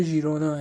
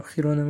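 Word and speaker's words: Girona: این تلفظ درست اسم این باشگاه ژیرونا اگر میبینیم منم خیرونا میگم بر حسب عادت و اشتباهه و خودم سعی میکنم اصلاحش بکنم درست تلفظ تلفظ درست در واقع همین ژیرونا هست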